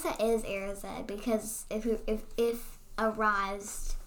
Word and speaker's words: Erised: How do you say the word E-R-I-S-E-D? – I say arised, but... I mean, is arise even a word?